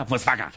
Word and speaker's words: Volkswagen: Volkswagen, se pronuncia Volkswagen.